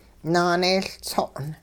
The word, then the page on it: naaneeyLtso'n: hupa